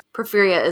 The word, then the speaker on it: porphyria: And then the last medical disorder that I'm going to discuss that may have fueled vampire speculation is porphyria. Right, James? That's how you say it? – Yep.